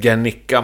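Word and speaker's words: Guernica: Med Gernica Mancini. – Ja. Är det ett bra uttal? – Ja, det tycker jag.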